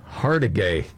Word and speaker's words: Hargitay: Oh, it's Mariska Hargitay or Hart. How do you pronounce her name? – Hargitay. Nice one.